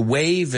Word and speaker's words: waive: To waive, es decir, me renuncio esto. Y este wave se escribe, se pronuncia igual que saludar con la mano, pero es W-A-I-V-E, right?